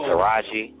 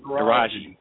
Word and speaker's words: Taraji: Taraji P. Henderson? – Oh, Taraji. – Taraji.